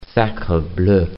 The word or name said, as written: sacre bleu